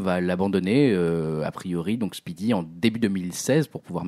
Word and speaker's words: SPDY: HTTP2, techniquement, c'est en construit partie sur un travail qui a été effectué par Google qui s'appelait SPDY, donc on prononce Speedy,